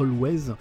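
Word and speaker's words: Alvvays: Le groupe s'appelle Always, c'est Blue Rave. L'extrait que j'ai choisi, c'est Pomarian Spinster. c'est écrit avec mais ça se prononce euh, Always.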